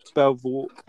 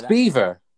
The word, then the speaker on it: Belvoir: Belvoir. Belvoir. Belvoir. – Belvobe? It's Beaver. Beaver Castle. – What? – Yes. – Interesting. – Yes. It is pronounced Beaver Castle.